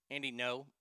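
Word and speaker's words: Andy Ngo: Oh, I learned how to say his name last week. That's why they don't want Andy's book sold. This is so dumb. The optics will make Andy No. Okay, that's the name, Andy No.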